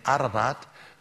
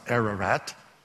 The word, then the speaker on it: Ararat: Harata in Santal sounds rather similar to the Armenian Ararat, which we mispronounce Ararat.